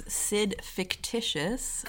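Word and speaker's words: Sid Fictitious: My Instagram is SidFictitious. – Good, like, fucking name. – You know, rhymes with Sid Vicious.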